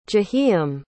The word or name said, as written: jahiem